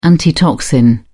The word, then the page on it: antitoxin: British English